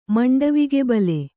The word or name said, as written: ಮಂಡವಿಗೆಬಲೆ